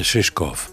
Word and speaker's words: Шишков: Да, да, да, правильно, ударение Шишков.